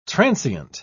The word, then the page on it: transeunt: British English